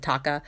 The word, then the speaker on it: Tocca: I guess it's, I think that's how it's pronounced. T O C C A. I pronounce it Taka.